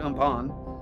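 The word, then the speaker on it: Amphan: The cyclone Umphan, it's A-M-P-H-A-N, but you pronounce it Umphan, And it's the cyclone.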